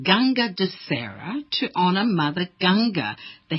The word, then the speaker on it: Ganga Dussehra: Well, thank you for joining us and talk to us about Ganga Dussehra.